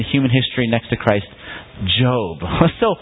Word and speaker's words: Job: I know it looks like Job, but in English, for some reason, we pronounce it Job.